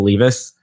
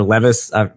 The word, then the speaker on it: Levis: Levis. And I don't trust, or Levis, excuse my pronunciation.